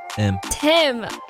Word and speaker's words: Thiim: Thiim.